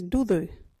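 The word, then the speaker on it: duthu: Our local Wajidi word today is dudu.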